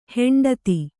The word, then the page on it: ಹಿಂಡೆತ್ತು: hiṇḍettu